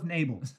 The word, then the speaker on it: Nabal: Nabal. We'll go with Nabal. Sounds like nasal. – Naval, but with a B. – Right. – This was from my American friends. That's American Sign Language. – Nabal.